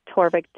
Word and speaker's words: Torvik: And that's spelled J-O-E-L-E-E-N-E? – J-O-E-L-E-E-N. Torvik, T-O-R-V as in Victor, I-C-K.